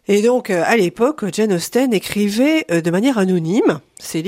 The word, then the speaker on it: Austen: Ça fait une dizaine d'années que j'avais envie d'écrire autour de Jane Austen. D'ailleurs, c'est chouette que vous disiez Austen et pas Austin, parce que ça s'écrit avec un E.